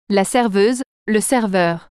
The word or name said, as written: serveur